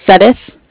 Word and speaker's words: Suddeth: Sudduth. – Can you spell that for me, please? – Yeah, S-U-D-D-E-T-H.